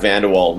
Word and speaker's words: Van De Walle: Well, it's Brad Van De Walle. My friends of 30 years can't even say it properly, so it's a tough one.